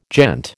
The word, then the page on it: gent: American English